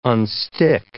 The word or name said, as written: unstick